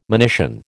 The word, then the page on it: monition: American English